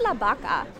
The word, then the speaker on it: La Vaca: It's spelled B-R-A-S-S-O-S on Pillier's map. – And here you have it with two S's. Here's La Vaca. – Which is spelled on the original map from Pillier as La Vaca, L-A-B-A-C-A. – And in Spanish, B and V, there's no sound of the V.